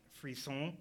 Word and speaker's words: frisson: It's called Frizzone. It's just you get this sort of tingling sensation in your brain, and it just I believe it's pronounced frisson.